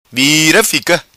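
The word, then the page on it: Vira Fica: patua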